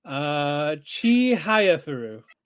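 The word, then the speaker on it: Chihayafuru: Manga Chia Hayafuru receives sequel in December. I'm pretty sure I pronounced it wrong. – How do you pronounce it? – Chi Hayafuru. – No, yeah, that's right.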